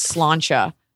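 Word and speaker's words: sláinte: And nobody was saying it right. And Mark said, everyone's saying like slank. Cause no one, it's not an American word. And I was like, you're a fucking stand-up comedian. You can do this. And I went up to like Pete and I was like, it's pronounced slancha. It's not slant.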